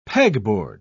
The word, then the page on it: pegboard: British English